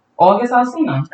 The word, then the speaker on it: August Alsina: August Alsina.